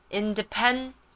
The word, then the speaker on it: independent: 所以 I found my role model of an independent, independent, ND, DI, 念成 DI 的音 D, independent, pen 就是笔，independent 才肯四个音节念，independent，independent，independent，OK？